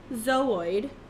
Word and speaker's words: zooid: So it is pronounced zooid. Zooid. – Zooid. Okay. – Zooid. It's spelled Z-O-O-I-D.